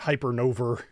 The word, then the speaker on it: Hypernova: I just totally pronounced that weird. Hypernova. So very New England of me.